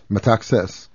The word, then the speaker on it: Metaxas: When I arrived home, I learned that Ioannis, how do you pronounce it? I'm sorry, Metaxas? – Metaxas, a Greek general and our prime minister, had disbanded the constitutional democratic government and imposed a dictatorship patterned after Mussolini's Italy.